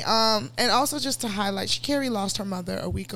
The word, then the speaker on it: Sha'Carri: So she, because I saw her do it in the interview, she pronounces her name Sha'Carri.